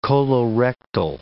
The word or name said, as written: colorectal